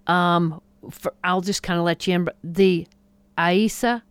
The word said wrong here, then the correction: Isaias: that's wrong. How do you say? – Isaias. – There you go.